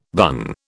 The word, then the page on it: bun: British English